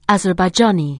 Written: Azerbaijani

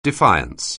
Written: defiance

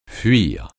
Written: fuir

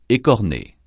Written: écorner

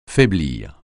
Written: faiblir

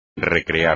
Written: recrear

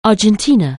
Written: Argentina

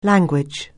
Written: language

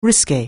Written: risqué

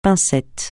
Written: pincettes

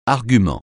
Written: argument

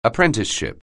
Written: apprenticeship

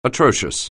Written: atrocious

